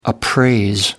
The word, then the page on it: appraise: American English